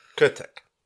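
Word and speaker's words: Krtek: And my pronunciation Come on. – Is Kurtek. It's a mole.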